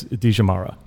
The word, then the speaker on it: Dija: And obviously we're sitting down here at, and again, correct me if I'm going to mispronounce it, feel free to hit. It's Dija. – Yes, correct.